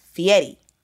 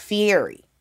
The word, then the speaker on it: Fieri: But I had to go back and re-record because his last name is pronounced Fieri. It's not Fieri.